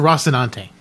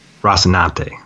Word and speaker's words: Rocinante: And Holden calls the ship from Tashi to Rocinante. Am I saying that right? – It's the Rocinante. – Rocinante, right? Thank you. I'm not good with Spanish.